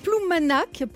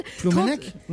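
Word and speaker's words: Ploumanac'h: Alors, avec surtout des températures qui explosent toutes les moyennes habituelles de saison, des valeurs historiques, 30,5 à Ploumanac, près de Perros-Guirec. Pardonnez-moi si vous êtes breton et que je prononce mal. – Ploumanac, Ploumanac, ouais. – Ah, Ploumanac, bah voilà, ouais, j'avais mal prononcé.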